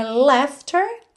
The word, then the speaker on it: laughter: Me, I'll take her laughter and her tears. Essa palavra laughter, também temos de novo o GH com som de F, olha. Laughter, L-A-U-G-H-T-E-R. E a pronúncia é bem simples. Laughter, laughter. Uhum.